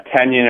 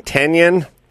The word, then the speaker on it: tenon: Why are you saying tenon? I think it's tenon. – Yeah, how do you pronounce it? – Tenon. – Tenon? – Yeah, Gary, look up Morris and Tenant. I think it's like tenant in an apartment or something. Like one. – It's not Tenon. – T-E-N-O-N, I think.